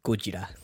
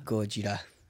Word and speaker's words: Gojira: Gojira. – Gojira. – Gojira. From now on, that's how we pronounce Japanese way.